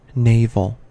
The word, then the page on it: navel: American English